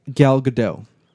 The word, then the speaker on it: Gadot: Gal Gadot. Apparently, you don't pronounce the T.